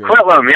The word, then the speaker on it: Coquitlam: So I guess the Matthew Goodman, they're actually from, you have to help me out with this, Coquitlam or something? That's butchered. – Coquitlam, yeah, that was close. Coquitlam, British Columbia, that is correct.